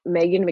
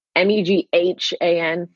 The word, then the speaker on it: Meghan: um, at Meghan McPeak on Twitter. M E G H A N for the listeners.